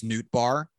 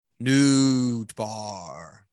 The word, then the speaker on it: Nootbaar: name of Lars Nootbaar also gets a World Series ring.